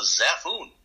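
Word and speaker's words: Xaphoon: And lastly, I'm now a distributor for a really cool musical instrument called the Zaphoon. I'll spell it for you. It's X-A-P-H-O-O-N.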